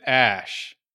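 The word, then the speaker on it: Æ: The AE, but it's pronounced, they pronounce it Ash.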